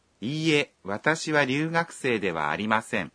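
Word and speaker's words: ইয়ে (いいえ): যখন আপনি বলবেন ইয়ে তখন এর ই অংশটি একমাত্রা লম্বা করে উচ্চারণ করতে হবে।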